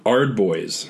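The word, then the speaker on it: Ard Boyz: I feel like you're auricing wrong when you actually pronounce the H. – H-O-Cams, I'm sorry. new, brand new for AOS, Oryx. Is that better? – No, Ardboys.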